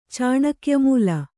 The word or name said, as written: ಚಾಣಕ್ಯಮೂಲ